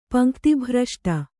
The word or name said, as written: ಪಂಕ್ತಿಭ್ರಷ್ಟ